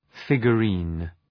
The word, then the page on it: figurine: ,fıgjə’ri:n